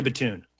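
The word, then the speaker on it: Batoon: And then their defensive coordinator, Corey Batun or Baton? How do you say it? – Corey Batun.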